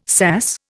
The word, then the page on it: sass: Indian